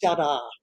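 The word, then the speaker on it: Jarā: The hunter's name is Jada, with a long A, a feminine noun.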